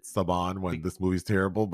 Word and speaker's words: Saban: Do you want to address how to pronounce Saban films, or is it Saban? – It's Saban. – Okay, but you know, being where I'm from, I grew up with a family whose last name was Saban, spelled the same way, pronounced that way.